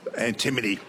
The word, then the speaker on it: antimony: I'd like to say antimony, was it? – Antimony. – Oh, my goodness. – Beautiful and well pronounced, too. That's fabulous.